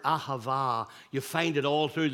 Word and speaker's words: ahava: This one, the Hebrew word which is pronounced ahava, means to give.